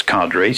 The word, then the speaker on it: cadre: His schedule is determined by a local party official, a cadre, sometimes pronounced as cadre, C-A-D-R-E.